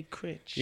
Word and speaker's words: Critch: Jay Critch.